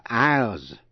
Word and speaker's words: aisles: Có chữ S, câm, không phát âm. Island là cù lao hay là đảo. I-S-L-A-N-D, island. Thứ hai nữa là isle. I-S-L-E là đảo nhỏ. Và aisles. A-I-S-L-E-S là những lối đi giữa hai hàng ghế hay phía bên của nhà thờ.